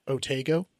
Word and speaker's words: Otago: In North, it's O-T-A-G-O, North Otago. – Yeah. – Otago. – Otago, I think, is Otago? How they say it there.